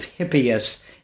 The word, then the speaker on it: Hippias: Moving on a little, what is the quadratrics of Hippias? Excuse me if I've mispronounced one or both of those words. – You've mispronounced neither.